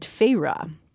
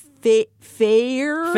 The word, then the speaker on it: Feyre: As the book opens, we're seeing things from the eyes of a young woman named Feyre, which thankfully, Sarah works a clever pronunciation of this name onto page 13 of the paperback, because I probably would have been calling her Feyre.